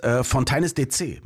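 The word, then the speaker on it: Fontaines D.C.: Und du hast es wirklich falsch ausgesprochen, ja, weil es ist Fontaines DC.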